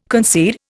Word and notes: concede: Indian

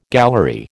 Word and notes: gallery: American English

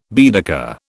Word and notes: Baedeker: British English

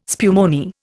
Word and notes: spumoni: Indian

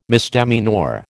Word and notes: misdemeanour: American English

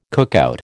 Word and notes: cookout: American English

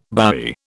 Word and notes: bowie: British English

